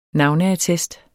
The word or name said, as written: navneattest